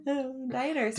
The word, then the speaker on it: Daenerys: Oh, is that how you say it? – Yes. – Daenerys.